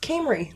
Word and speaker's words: Cymru: And then what I thought was Cymru, C-Y-M-R-U, pronounced Camry.